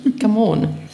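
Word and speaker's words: Gamon: Nein, aber meinen Namen spricht man anders aus, nämlich Gammon.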